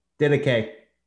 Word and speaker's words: Didache: Now, you'll have to remind me, how do I pronounce this word again? – Didache. – Didache, the teachings of the early church fathers.